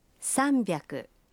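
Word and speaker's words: Самбяку (さんびゃく): Правильно, но с произношением может возникнуть путаница. 300 произносится? – Самбяку.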